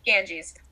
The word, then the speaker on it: Ganges: Ganges Ganges? Ganges. that, thank you.